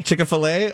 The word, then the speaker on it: Chick-fil-A: Oh gosh, I would have to say the Chick-fil-A. How do you say it? – Chick-fil-A.